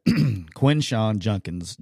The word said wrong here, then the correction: Judkins: Judkins, I get killed for pronouncing Quinshawn Judkins.